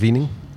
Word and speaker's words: Weening: Weening looks like weaning, but I think they pronounce it Weening.